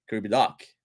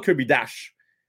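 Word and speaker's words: Kirby Dach: En passant, j'ai écouté bien comme il faut euh, Pierre Hood et il dit Kirby Doc. Peut-être un petit peu moins prononcé que moi le Doc, mais Doc, euh, Kirby Doc, Doc, Kirby Doc. Il dit quand même Doc. Il dit pas Kirby Doc, puis il dit pas Kirby Dash.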